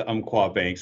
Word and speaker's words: Umpqua: And first of all, thank you for saying it correctly. It is Umpqua Bank, so I will make sure that's clear.